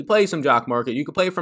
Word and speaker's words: Jock Market: And Jock Market, as it rhymes with stock market, is exactly that.